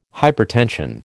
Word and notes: hypertension: American English

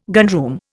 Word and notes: gunroom: Indian

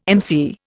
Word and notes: MC: Indian

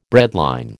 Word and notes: breadline: American English

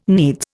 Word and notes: needs: Indian

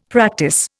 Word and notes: practice: Indian

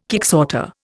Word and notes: kicksorter: Indian

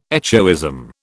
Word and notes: echoism: British English

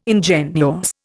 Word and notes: ingenuous: Indian

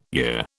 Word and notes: year: British English

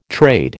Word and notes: trade: American English

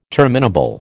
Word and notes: terminable: American English

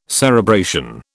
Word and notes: cerebration: British English

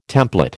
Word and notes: template: American English

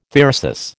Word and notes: thyrsus: American English

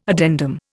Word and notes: addendum: Indian

